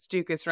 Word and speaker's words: Stukus: He let us know that Stukus rhymes with mucus, and I couldn't not repeat it.